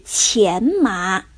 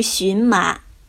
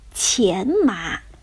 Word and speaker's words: 荨麻: 艾丽莎用来编织披甲的植物是前麻，而不是荨麻。前麻的“ 前” 字。上面一个草字头，下面是一个寻找的“ 寻”，这个字呢是多音字，既可以读作“ 钱”，也可以读作“ 寻”，但是在这里呀，它正确的读音应该是“ 钱”，钱麻。